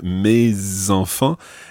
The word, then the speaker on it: mes enfants: Mes enfants. It's called liaison. So the S of me follows on and you pronounce it because it's followed by an E.